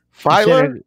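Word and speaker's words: Feiler: But I thought that Matt Filer. – Yes. – Filer? Yes. – You said it right, yeah.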